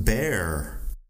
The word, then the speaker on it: bear: Luego tenemos bear Bear es oso, bear, y se pronuncia con el diptongo ea, ea. Piénsalo un poco como la palabra de aire, aire, air, bear.